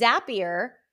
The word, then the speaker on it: Zapier: And I see that like one of the integrations is Zapier, rhymes with happier.